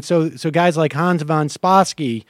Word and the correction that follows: Spakovsky: I can't pronounce his name, but Spakovsky, yeah. – Yes, Spakovsky.